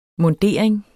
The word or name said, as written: mundering